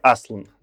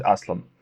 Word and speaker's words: Аслан: Надо поправить, э, вроде что ты, говорил, тогда правильное произношение все-таки Аслан должно быть. – Правильно, Аслан? Ну хорошо, пусть будет Аслан.